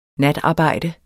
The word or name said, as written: natarbejde